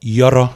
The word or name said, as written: Yawraw